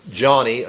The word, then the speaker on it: Joni: How is it possible for someone like Johnny Erickson Tada, you know, it's pronounced Johnny, even though it's spelled J-O-N-I,